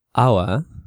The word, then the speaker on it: hour: Okay, and be careful here because a lot of people, you know, the word hour, la hora, you know, H-O-U-R, it's got an H, tiene una H, but it's silent, okay? No se pronuncia, es una H muda.